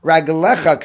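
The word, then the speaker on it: Raglecha (רגליך): Now in the second version, you can go back in, dechzivim tashima shabbas raglecha, Raglecha Karinan. The Kri, the pronunciation is Raglecha, even though we have a Mesorah of writing it without a Yud, but the pronunciation is with a Yud, and so therefore it's plural.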